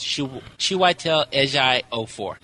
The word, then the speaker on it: Chiwetel Ejiofor: Chiwetel Ejiofor. – So I was close. – Yeah, you were close. Chiwetel Ejiofor.